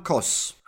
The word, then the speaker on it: Kos: My name is Adam Kos. – How do you spell that, please? – It's A-D-A-M-K-O-S.